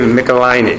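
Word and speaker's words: Michelinie: would everybody please welcome Dave Michelini. – I got that right there. You pronounced it right, too.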